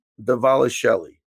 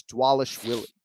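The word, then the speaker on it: Dvalishvili: Uh, the V's are pronounced as W's, so it's Dwalish Willie.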